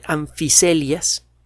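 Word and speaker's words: Amphicoelias: El nombre que se le dio en aquella época fue anfiselias, la F se, escribe PH, anficoelias, así se escribe, va a encontrar usted una entrada en la Wikipedia.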